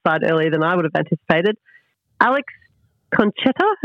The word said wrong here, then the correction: Cincotta: am I saying that right? – Cincotta, there we go. Thanks, Maddie. Alex Cincotta, the defender that we plucked from our VFL.